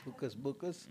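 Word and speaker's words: Hukus Bukus: It's called, I don't know how to pronounce it, it's Hucus Bucus or Huckus Buckus. – Like Hocus Pocus, it is Hucus Bucus.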